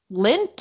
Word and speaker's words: Lindt: But like, have you never had a Hershey bar or Cadbury or like Lindt, or is that how you say it? L-I-N-D-T.